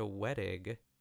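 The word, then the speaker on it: Wettig: She lost to Patricia Wedig in 30-something. I hope that's how you pronounce it. That is how you pronounce it, yeah.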